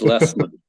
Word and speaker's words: Lesmeister: And Joe, I'm not even going to try to pronounce your last name because I'm going to butcher it. – It's Lesmeister. – I would have probably butchered it. – Many people do. – It's German? – It is German, yeah.